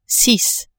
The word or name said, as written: six